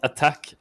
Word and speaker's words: Attack: Tydligen ska det uttalas Attack, läser jag nu också på Liquipedia.